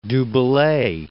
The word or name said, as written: Bellay, du